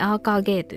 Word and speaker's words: AKG: AKG と書いてアーカーゲーと読むえっ、